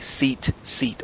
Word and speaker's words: tzitzit: I've spelled it T-Z-I-T-Z-I-T. So, tzitzit. But you can pronounce it as if you're looking at two seats and you just say them back to back rapidly. Seat, seat, or seat, seat. So some people say seat, seat.